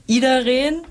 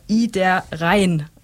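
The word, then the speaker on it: Iedereen: Und es wird eigentlich ausgesprochen, so schreiben sie es auf ihrer Webseite, Idareen. Aber alle sagen es falsch, zum Beispiel wie ich, Idareen oder andere auch Idareen.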